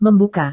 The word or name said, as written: membuka